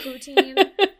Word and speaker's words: poutine: The poutine.